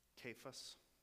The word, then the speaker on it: Cephas: Cephas, who's Peter, by the way? And it's pronounced with a hard K, just so you know. It's a kappa in the Greek. Which is like a K, Cephas.